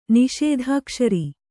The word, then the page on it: ನಿಷೇಧಾಕ್ಷರಿ: niṣēdhākṣari